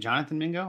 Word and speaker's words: Jonathan Mingo: Wasn't it Jonathan Mingo? Am I saying that right? – Yes, that's right.